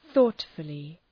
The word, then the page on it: thoughtfully: ‘ɵɔ:tfəlı